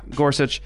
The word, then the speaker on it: Gorsuch: Gorsuch.